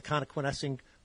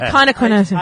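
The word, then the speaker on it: Connoquenessing: And in his journal on November 30th, 1753, it said, I crossed the creek, which we know from Indian trails was the Conequenessing Creek. We think you say that so well. I never say it right. I'm always like Conequenessing.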